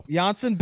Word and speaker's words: Janssen: So Janssen, big soft J guy, loves yogging as well.